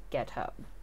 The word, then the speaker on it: Guiteau: Enter, at this point, Charles Guiteau. G-U-I-T-E-A-U. It's pronounced Ghetto.